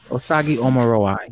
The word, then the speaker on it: Osagie Omorowa: Let me make sure I pronounce his name. Omorua? Make sure I get this brother's name. Omorua. Yeah. Osagi Omorua.